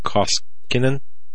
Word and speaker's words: Koskinen: You remember John Kosecan. Yeah, John Koskinen. Yeah, that's how you pronounce his name.